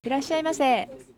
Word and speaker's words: いらっしゃいませ: いらっしゃいませ.